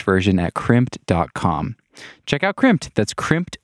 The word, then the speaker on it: Crimpd: Crimped is spelled C-R-I-M-P-D. That's crimp with a D at the end.